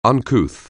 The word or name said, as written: uncouth